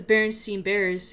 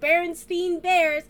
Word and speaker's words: Berenstain: How could every education system pronounce it Berenstain Bears, including the lady in the children's Barnes & Noble section? She's very dedicated to her job. She calls it Berenstain Bears!